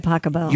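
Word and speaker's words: Pachelbel: Canon D by Pasquale. – By Pacabell. – You pronounce it Pasquale? – No.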